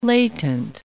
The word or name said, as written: latent